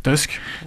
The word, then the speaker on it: Tusk: Ce qui saute aux yeux, c'est que le président du Conseil européen, qui s'appelle Donald Tusk, je sais plus le prononcer, Tusk. – Tusk. – Tusk, ouais, je sais pas, il faut le prononcer à l'anglaise parce qu'il est pas anglais, est polonais. Et non seulement il est polonais, le président du Conseil européen, mais il a été chef du gouvernement.